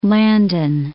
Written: Landon

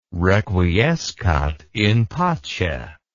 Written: requiescat in pace

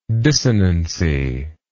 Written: dissonancy

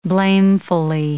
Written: blamefully

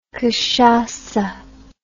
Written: cachaca

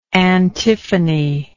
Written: antiphonically

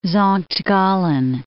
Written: Sankt Gallen

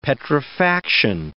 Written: petrifaction

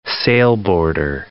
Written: sailboarder

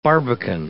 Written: barbican

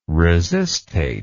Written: resistate

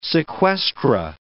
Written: sequestra

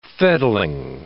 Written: fettling

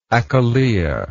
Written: achillea

